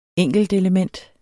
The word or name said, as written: enkeltelement